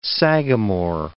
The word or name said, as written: sagamore